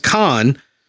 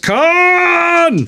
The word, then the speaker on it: Khan: They're going up against Khan, who they went up against in the original series. – You didn't say that right. – They went up against Khan. – That's better.